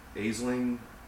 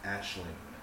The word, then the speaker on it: Aisling: Dana said that you, Travis, you recast in one of the Grindhouse films You pronounced it Aisling Franciosi. – Okay. – I think that she says that the first name is pronounced Ashling.